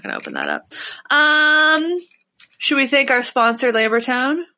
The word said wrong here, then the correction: Lambertones: What's with you and never getting his name right? – It's called Lambertones. – Lambertown Pickups. There's an S.